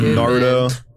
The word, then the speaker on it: Naruto: I've seen a little bit of Naruto. – Naruto. – Just say it like a normal guy. Naruto.